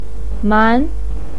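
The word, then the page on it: man: tone 2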